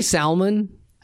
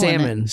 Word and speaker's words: salmon: That's how you say it. It has an L. Salmon. Salmon.